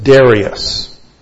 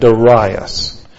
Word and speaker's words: Darius: And by the way, there are two pronunciations of the word Darius. Some people pronounce it Darius, and other people pronounce it Darius. I grew up hearing it Darius.